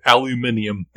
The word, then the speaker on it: aluminium: How do they say it again? – Aluminium. – Aluminium. – They pronounce all the vowels.